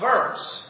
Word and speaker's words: Worms: Now, it's spelled Worms to us, but it's pronounced Worms.